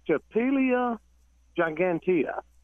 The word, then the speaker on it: Stapelia gigantea: But that particular one is Stapelia gigantea. – Right, probably not pronouncing it correctly like you do. – You got it.